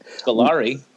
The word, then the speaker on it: Scolari: And Peter Sclarelli. That's how you say it. Scolari.